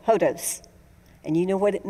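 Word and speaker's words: hodos (ὁδός): And the Greek word for road or way or route is a word pronounced hodos. It's H-O-D-O-S phonetically.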